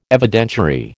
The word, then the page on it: Evidentiary: American English